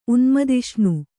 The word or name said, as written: ಉನ್ಮದಿಷ್ಣು